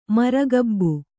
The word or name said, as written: ಮಾರಿಕಬ್ಬೆ